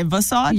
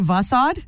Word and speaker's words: facade: I think is it fa? – Facade. – Or is it va? – Facade. – Is it? – Facade. – I think I've been saying it wrong my entire life.